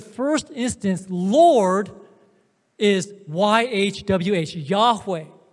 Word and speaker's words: Yahweh: And most likely the pronunciation is Yahweh.